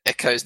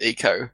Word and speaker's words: Echo: Tom Baker does the narration of Defender of the Future, and he introduced me to the fact that Echo's name is pronounced Echo, whereas all through my childhood I'd been pronouncing it Eco.